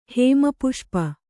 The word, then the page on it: ಹೇಮಪುಷ್ಪ: hēma puṣpa